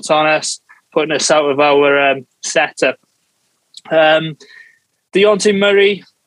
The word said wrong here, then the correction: Dejounte Murray: is that how you say it? He's got one of those names, hasn't he, that you can't really say? DeJounte Murray? – DeJounte Murray, yeah.